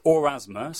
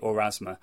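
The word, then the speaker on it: Aurasma: Okay, so it's Orasma, so it's A-U-R-A-S-M-A, Orasma.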